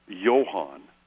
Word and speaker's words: Johann: Jan, my cousin's name, they're German, it's Johann. – Right. – Which would be closer to Johannen or Johannon for the John of the Bible. Instead of the hard J, it was a Y sound.